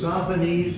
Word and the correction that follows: Sosthenes: is that how it's pronounced? Sophonies? – Sosthenes. There's an extra S in there.